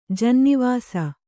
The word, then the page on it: ಜಿನ್ನೀವಾಸ: jinnī vāsa